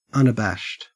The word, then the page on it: unabashed: Australian English